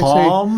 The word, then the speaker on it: calm: C-A-L-M? – C-A-L-M makes you calm. – Just doesn't matter. – I'll have to pronounce the L. Calm. – Yes, calm.